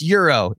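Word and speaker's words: gyro: Knicks fans or people from New York, if you see the name of the podcast and it's G-Y-R-O, that's not the sandwich that you like to get on the corner of most places in the city. It's pronounced Euro in different parts of the country.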